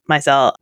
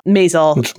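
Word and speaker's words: Maisel: Leanne got the rebound with The Marvelous Mrs. I think she also pronounced it Mizell. Mazel is how it is, you know, but if you haven't seen it, if you haven't heard it pronounced, you've just seen it written.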